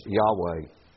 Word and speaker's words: Yahweh: Whenever you see the word Lord in all capitals, that stands for God's proper name, which is revealed to us in Scripture to be pronounced something like this, Yahweh.